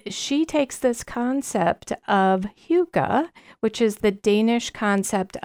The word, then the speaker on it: hygge: So it looks like Holy Hygge or Hygge or something, but it is actually a Danish word. – Oh. – And it is pronounced Hygge.